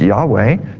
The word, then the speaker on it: Yahweh: Now, the Lord is Jehovah in the Old Testament, sometimes pronounced Yahweh, sometimes Jehovah. The original name for God has no vowels in it, so you can't really pronounce it. And the Jewish people did that on purpose because they thought the name of God was too holy to pronounce. So through the course of time, folks put vowels in, and one way to say it is Jehovah and another way is Yahweh.